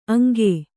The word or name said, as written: ಅಂಕೆ